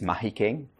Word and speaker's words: Mahikeng: Thursday, there were these protests that started to happen in Mafikeng, or I'm saying it wrong, it's Mahikeng. Spread, and then we didn't see any of it here in this particular area where we are.